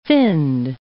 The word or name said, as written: finned